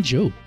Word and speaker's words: Jope: Marion Jope. – Yes. – Yeah, it's soap with a J.